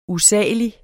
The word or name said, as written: USALIGT